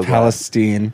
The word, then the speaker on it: Palestine: But it's pronounced so Palestine.